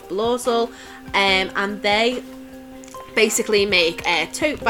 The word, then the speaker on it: Blaucel: They're on Insta, they're on Etsy, it's Blossel. And I know it's a bit of a nice avant-garde style name, so it's spelled BLAUCEL. That's Blossel on Instagram and Etsy.